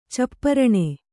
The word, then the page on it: ಚಪ್ಪರಣೆ: capparaṇe